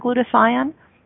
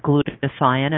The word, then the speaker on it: glutathione: If you Google the word glutathione, and I always call it like glute, like your glutes, a-thion is the pronunciation.